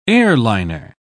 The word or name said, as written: airliner